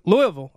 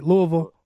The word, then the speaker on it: Louisville: So, um, lay out, and you're from you're from Louis, uh, Louis, Louisville. I better say it right, Louisville, Kentucky.